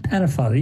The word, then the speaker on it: Pennefather: Pennefather, you got it.